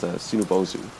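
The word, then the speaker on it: Sunabozu: One such person is a math handyman, Desert Punk, also known as Tsunobozu, or that's how they pronounce it in Japanese.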